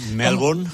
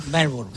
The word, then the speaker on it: Melbourne: ¿Cómo se pronuncia en inglés? Que yo estoy peleado con esta lengua bárbara. – Melbourne. – Melbourne.